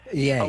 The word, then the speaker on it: Ye: That said, you know, by dining publicly at his resort home with Kanye West, known as Ye or Yee, not quite sure how to pronounce that. – Yay. – With a posse. Yay, excuse me.